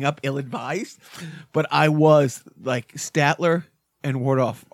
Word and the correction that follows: Waldorf: are just... – Waldorf. – That's, I always say it wrong.